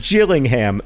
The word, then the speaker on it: Gillingham: It is pronounced Gillingham, soft G like jam. And that's important because Gillingham, hard G like girl, is a different town in England.